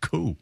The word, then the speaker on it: coup: And more intel on the Palace Q. And thank you for all the emails saying that's actually pronounced coup. – Coup.